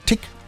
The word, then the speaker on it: Tich: T-I-C-H wird Tick ausgesprochen. Ja, so wie Tick-Tack, Tick-Tock, Tack, Tick-Tack, Tick.